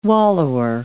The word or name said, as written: wallower